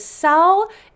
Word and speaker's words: Salle: there are records that this Simon guy held lands in the Salle. That's S-A-L-L-E. I looked it up. It is pronounced Salle.